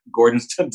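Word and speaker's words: Gordonstoun: Gordonston. You're talking about the Scottish. – And the Colditz Castle. – Right. Well, Colditz Castle was a German prisoner of war camp, and he likened Gordonston to that.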